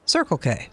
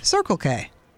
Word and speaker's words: Circle K: It's the way you put the emphasis on the first syllable. – Yeah. Circle K. – Circle K.